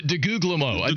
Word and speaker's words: DeGuglielmo: What was it? Guglubio? – DeGuglielmo. – DeGuglielmo.